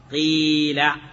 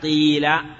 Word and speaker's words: قيل: واللغة الثالثة ما هو؟ إشمام الكسرة شيئا من رائحة الضمة. نعم. هذا أيضا يكتب كما يكتب الكسر الخالص، يكتب بالياء، لكن يعني ينطق بالحركة بين الكسرة والضمة، لا يقال قيل بل قيل بيع، هكذا هكذا ثلاث لغات.